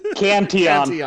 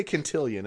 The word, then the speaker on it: Cantillon: Cantillon. – I always want to say Cantillion, and I know that's wrong.